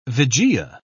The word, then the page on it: vigia: British English